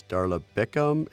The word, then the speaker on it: Darla Biccum: Joining us is Darla Biccum.